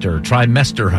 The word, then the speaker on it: trimester: Question two, Gabby Barrett says when she performs her song, I Hope, on the CMA, she'll be in her final trimester of her pregnancy. The trimester. How many trimesters are there? – There are three trimesters. – Putting the wrong emphasis on the wrong syllable there.